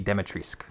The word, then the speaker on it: Dimitrescu: Demetrius, is that her name? – Yeah, I think Brittany Brombacher, who hosted the showcase, you know, the first one and this most recent one, she pronounced it as Lady Dimitrescu.